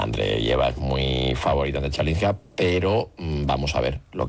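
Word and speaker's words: Chwalińska: Y mañana la final femenina, a partir de las 3 de la tarde, entre Andreeva, y a ver si lo pronuncio bien, Chvalinska. – Sí, está bien dicho, la verdad.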